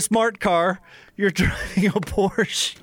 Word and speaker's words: Porsche: It's a Porsche. – You drive a Porsche, and you just corrected me on the pronunciation of the name.